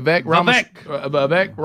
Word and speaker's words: Vivek: Last night, the highlights, Vivek, is that how you say his name? – Yep. Vivek Ramaswamy.